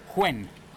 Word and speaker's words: Nguyen: Yeah, and actually I've worked with somebody from Vietnam who said you pronounce it Huen. – Ah, you do? – Huen, yes, but it's Vietnamese name, Nguyen.